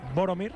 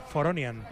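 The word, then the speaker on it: Voronin: Boromir. – Sí. – Pues la pronunciación es Foronian.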